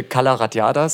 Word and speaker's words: Cala Ratllada: Es müsste Cala Ratllada sein.